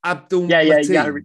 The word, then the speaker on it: Abdul Mateen: Is it Abdul Mateen? – Yes.